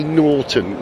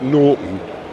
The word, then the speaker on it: Norton: Have you noticed how I say Norton, not Norton, which is what I used to say? Got to pronounce the T, right?